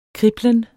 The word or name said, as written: kriblen